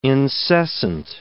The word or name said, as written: incessant